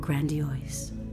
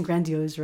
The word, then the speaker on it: grandiose: Created to exist as you are, we are grandiose. Now, am I pronouncing grandiose right? – You did just now. – Oh, but not in the poem? – Not really, no.